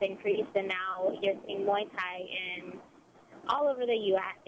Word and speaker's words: Muay Thai: So I guess my first question to you is, and it's the fight tomorrow in Richmond is Muay Thai. Am I pronouncing that correct? – Muay Thai.